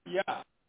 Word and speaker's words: Yah: Now, but they have it spelled as Yah, but it's pronounced what? Yah. Because there is no J sound in Hebrew.